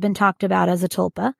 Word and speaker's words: tulpa: Thought forms and tulpas. A tulpa is something that, am I pronouncing that right? Mm-hmm. Tulpa? – Yep.